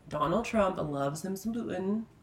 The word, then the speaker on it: Putin: But like, Putin, is that how you say his name, from Russia? – Yeah.